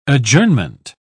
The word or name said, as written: adjournment